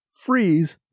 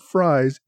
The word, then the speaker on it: Fries: After the show, several locals pulled me aside to assure me that Freeze, not Fries, is the correct pronunciation.